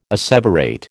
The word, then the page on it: asseverate: American English